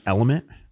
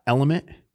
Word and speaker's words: LMNT: The first company I'm going to recognize is Element. That is spelled L-M-N-T. Element.